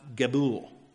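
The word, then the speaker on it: Gabul (גבול): The term quarters here in verse 7 of Exodus 13 is from the Hebrew word that is spelled G-B-U-W-L. It's pronounced Gabul.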